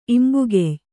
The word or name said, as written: ಇಂಬುಗೆಯ್